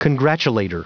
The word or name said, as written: congratulator